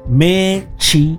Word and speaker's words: México: La siguiente se llama México, que significa el ombligo de la luna, y está escrito así, es como se pronuncia re realmente México. – México.